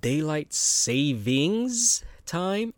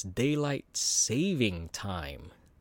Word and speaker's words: daylight saving time: And so it's not daylight savings time, it's daylight saving time. So I didn't even know that. Mind blown, you know? So I've been saying it wrong all these years. There is no S in saving except for the first S. But there's no savings, it's saving.